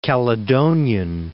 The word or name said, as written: Calydonian